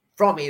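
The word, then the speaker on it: Fromme: On the first day of the trial, Squeaky Frommy, or Lynn Squeaky Frommy is the way she likes her name to be pronounced, said to me, she knew I'd written the book, that she threatened me.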